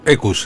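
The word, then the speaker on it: Equus: La obra era nada menos que Ecus. Creo que, ¿se dice así? – Ecus, sí.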